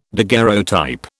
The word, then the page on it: daguerreotype: British English